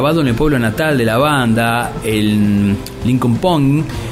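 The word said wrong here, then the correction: Linköping: eh, ¿cómo se dice? Lincoln Ping, producido por Jim Walker.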